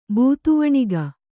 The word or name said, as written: ಬೂತುವಣಿಗ